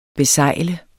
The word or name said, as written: besejle